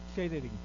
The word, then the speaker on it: catering: ¿Catering o catering? – Sí, catering.